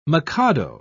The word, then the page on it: mikado: British English